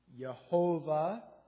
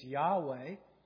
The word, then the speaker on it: Yahweh: Yahweh is one pronunciation of it. Yehovah is another pronunciation. The more accurate pronunciation is Yahweh, because Jehovah takes the pointing from the word Adonai and applies it to the divine name.